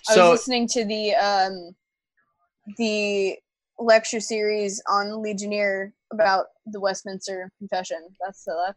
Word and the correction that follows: Ligonier: So it's pronounced Ligonier.